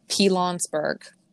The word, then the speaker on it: Pilanesberg: Pilanesberg.